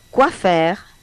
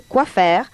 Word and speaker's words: quoi faire: Que en francés es quoi faire, pronunciación aproximada, sí, es quoi faire.